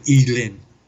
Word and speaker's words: Y Glyn: Uh, we went to the New Inn and uh, Smiles' house, if I'm correct, is is it pronounced Y Glyn? in uh, as as it's written. I don't know how you pronounce it in Welsh. – Yeah, I, th- I think in Welsh, I think it's more accurate to pronounce it Elin. – Elin. Y is is pronounced as E.